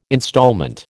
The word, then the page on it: instalment: American English